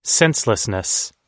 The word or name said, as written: senselessness